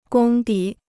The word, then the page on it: 功底: gōng dǐ